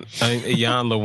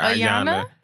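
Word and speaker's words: Iyanla: When Ayanla, when Benzino's wife, baby mother, something, whatever she is, she was on there and she kept saying her name wrong. And she kept calling her Ayana. Yeah. And she'd be like, E, like Ayanla would like try to sound it out where she's like, E, E, Yanla, nah.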